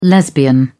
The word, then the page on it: lesbian: British English